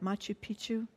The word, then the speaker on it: Machu Picchu: Machu Picchu? – Yeah, you did.